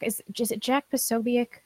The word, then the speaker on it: Posobiec: It reminded me of like Jack Posobiec. Is that how you say his name? – Yes.